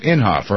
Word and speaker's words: Inhofe: That's how you say it, Inhofe or Inhofe? Inhofe, right? – Yeah, Inhofe.